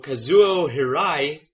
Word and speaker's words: Kazuo Hirai: Sony Computer Entertainment's new CEO, Kazuo Hirai. Am I saying that right? – Mm-hmm. – Okay, good. – Sounds good to me.